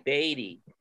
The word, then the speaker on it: Baty: This Brett Beatty, B-A-T-Y, pronounced B-A-Y-D-E-E, Beatty.